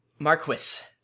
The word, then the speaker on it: Marquis: Is that how you pronounce that? – Marquis. – Marquis? – Marquis? – In Final Fantasy XII, they said Marquis. So that, I was like, that's not how you say it. – Actually, that is a valid pronunciation, apparently.